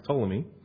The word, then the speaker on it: Ptolemy: The two that are left, you'll have Ptolemy, which is spelled with a P, so just know that when you see history. It looks like Ptolemy, but it's pronounced Ptolemy.